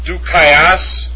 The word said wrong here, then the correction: Dikaiosune: Hold on a second. Dukaiosune. Oh, I'm sorry. Let me take that back. Dikaiosune. Sorry. Dikaiosune. It's got an iota. That's dikaiosune with the accent over the upsilon.